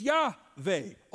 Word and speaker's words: Yahweh: And so our best way of pronouncing it is Yahweh or Yahweh.